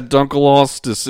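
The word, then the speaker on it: Dunkleosteus: This one, Dunklistos. – Dunklistos. – Dunklostos. – All right. – How do you pronounce that? Dunklostos? – Yeah, it looks like a big walrus. – Yeah, Dunklostos.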